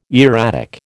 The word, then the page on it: erratic: American English